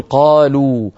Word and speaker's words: قالوا: قالوا ليس نجد بعد الواوي ألفا يسميها العلماء ألف التفريق، ولا تنطق هذه الواو، تكتب ولا تنطق، لا ينطقها أحد، ما في أحد يقول قالوا مثلا، كل الناس تقول قالوا، مع أنهم يكتبون ألفا بعد واوي الجماعة ليبينوا بأن هذه الواو واو جماعة وليست من أصل الفعل.